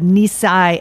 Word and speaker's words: Nisei: which is when the Nisei and the Isai, am I saying that right? – That's correct.